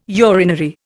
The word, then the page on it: urinary: Indian